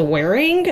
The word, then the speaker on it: Whering: Part of me is afraid I said this one before, because I use this thing all the time, but there's this app called Wearing. It's spelled really funky. It's spelled W-H-E-R-I-N-G.